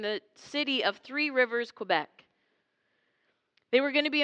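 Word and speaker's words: Québec: That's how they say it, Quebec.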